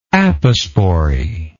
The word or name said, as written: aposporous